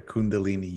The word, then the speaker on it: Kundalini: Okay, Kundalini yoga.